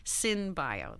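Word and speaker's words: Cynbio: It's C-Y-N-B-I-O. Oh, I see. Synbio. That's how you say it.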